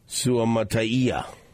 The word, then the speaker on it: Suamataia: Kingsley Suamataia.